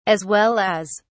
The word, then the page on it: as well as: British English